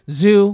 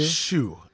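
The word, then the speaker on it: ZHU: Zoo with an H, Z H U. – Shoe, as we pronounce it.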